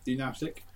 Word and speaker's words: pneumatic: So the choices are pneumatic. Am I saying that right? – Yeah. Yep. Pneumatic.